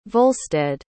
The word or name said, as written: volstead